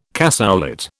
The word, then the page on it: cassoulet: British English